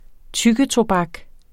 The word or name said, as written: tyggetobak